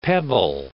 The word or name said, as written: pebble